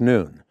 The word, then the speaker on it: noon: But that pin essentially has the 14th letter of the Arabic alphabet on it, pronounced noon.